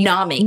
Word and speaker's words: NAMI: NAMI.